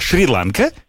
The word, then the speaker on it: Шри-Ланка: Шри-Ланка. А кто-то еще говорит Шри-Ланка. Ударение, как оказывается, ланка, можно ланка. И так, и так, да. Но на Ланка больше, более распространенный вариант.